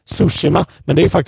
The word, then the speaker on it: Tsushima: Det uttalas Sushima.